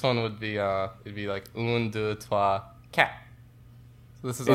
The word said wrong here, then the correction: quatre: Isn't it quatre? – Cat. – It's quatre. – No, that's not. It's pronounced cat. – No. – It's spelled. Are you sure? – I'm pretty sure, Max. Un, deux, trois, quatre, cinq, six, sept, huit, neuf.